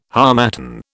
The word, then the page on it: harmattan: British English